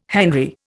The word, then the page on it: henry: Indian